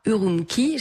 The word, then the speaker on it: Urumqi: Après 50 jours de fermeture, le Golden Palm Cinema a Urumqi, j'espère que je le prononce bien. – Très très bien.